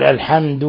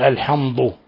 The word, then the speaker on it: الحمد: تفخيم حرف الدال من الحمد فيقول بدلا يقول الحمد يقول الحمض الحمض فيفخم الدال تفخيما يقارب يعني الضاد